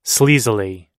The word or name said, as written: sleazily